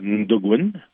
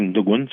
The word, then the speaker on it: Natukun: Fait tout ça pour aussi pour mon album en fait qui se prononce Ndogun. Ça s'écrit Natogun, mais on dit Ndogun, ça veut dire un, remède ou un médicament.